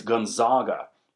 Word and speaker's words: Gonzaga: I'm not going to say Gonzaga or however they would say it in Wisconsin. I'm Canadian. We speak the Queen's English. It's Gonzaga.